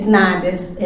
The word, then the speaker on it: Nondas: It's Nondas, N-O-N-D-A-S.